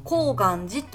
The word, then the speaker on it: 高岩寺: ここは高い岩にお寺で高岩寺と読むそうなんです